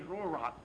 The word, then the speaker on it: Ruach: The Hebrew pronunciation of Holy Spirit is Holy Ruach. Want to say it? Holy Ruach.